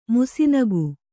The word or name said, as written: ಮುಸಿನಗು